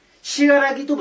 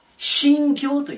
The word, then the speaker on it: 信楽: これ、信楽と読まずにね、しがらぎとも読みません。信教と読みます。